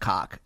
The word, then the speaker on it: Koch: Doing yeah by the way Coke brothers, we know it's actually pronounced cock. Stop it, guys.